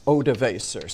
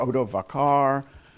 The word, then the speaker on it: Odoacer: What was new is that this chieftain, whose name is spelled all sorts of different ways, but in Wickham, it's Odoacer. Sometimes he's known as Odoacer, Odovacar. Odoacer, aren't even sure what so-called tribe he belonged to.